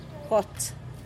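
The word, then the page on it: gots: Teotitlán del Valle Zapotec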